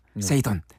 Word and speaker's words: Poseidon: 근데 포세이돈이 되게 빨리 나와요. 세이돈, 세이돈 이렇게 나옵니다. – 아, 거의 P가 발음 안 되네요.